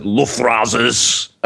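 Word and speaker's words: Luftrausers: and I pronounce it Luftrazers.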